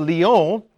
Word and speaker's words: Lyon: Originally from Smyrna, which is in present-day Turkey, he became bishop of Lyon in Gaul, which is present-day France. That's why it's Lyon, because the French don't pronounce all the consonants, right? They leave the last ones off.